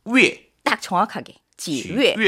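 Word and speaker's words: 위에 (月): 월, 월. 발음이 중요해요. 자, 네. 위. – 위. – 위에. – 위에. – 자, 입 모양은 우로 하시고요. – 우. – 그쵸. 발음은 위로. – 위에. – 그렇죠. – 위에.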